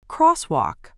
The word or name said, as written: crosswalk